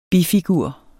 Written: bifigur

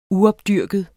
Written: uopdyrket

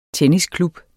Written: tennisklub

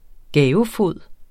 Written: gavefod

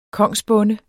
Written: kongsbonde

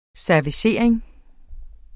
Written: servicering

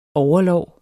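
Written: OVERLÅR

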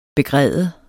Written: begræde

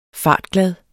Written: fartglad

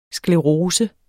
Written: sklerose

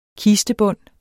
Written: kistebund

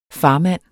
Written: farmand

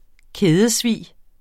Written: kædesvig

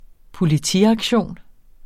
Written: Politiaktion

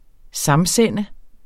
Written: samsende

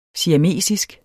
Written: siamesisk

